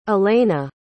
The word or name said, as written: alena